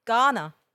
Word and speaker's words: Garner: Not like Gardner. – I'm pretty sure you pronounce every letter in that last name. Gardner. – So what did you think I said? – Gardner. – Yeah. It's Garner.